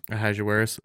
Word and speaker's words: Ahasuerus: Before the king, and King, how do you say his name? – Ahasuerus. Or you can just say, I would just say Xerxes, because that's his Greek name that most people know him for.